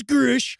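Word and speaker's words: Grusch: We'll say it correctly. It's David Charles Grush. – It's Grush.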